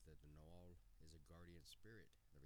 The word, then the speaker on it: Nahual: Werewolves in Mexico are known as the Nahual or Nugal. Both are pronounced Nahual, so I said it wrong.